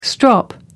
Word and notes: strop: American English

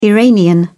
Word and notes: iranian: British English

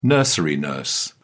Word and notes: nursery nurse: British English

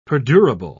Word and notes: perdurable: British English

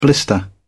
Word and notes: blister: British English

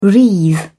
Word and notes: wreathe: British English

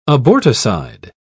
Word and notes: aborticide: British English

